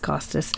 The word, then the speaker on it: Costas: I think it's pronounced Costas. – Yeah.